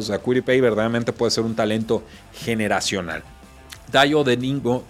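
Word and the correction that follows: Dayo Odeyingbo: de eh, Vanderbilt, no, creo que no lo pronuncié bien, Dayo o de Jingbo, mejor dicho,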